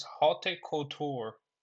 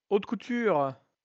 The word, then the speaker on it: haute couture: And this one from Amnishki, which I do like, is Haute Couture embroidery, which is like a French style of embroidery. That one's out of. I have to say, I don't know how to say this. – Haute couture.